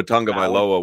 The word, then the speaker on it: Tagovailoa: It's like Tua Tagovailoa, where there's no N in the name, but there's an N when it's pronounced. – So the N is a G.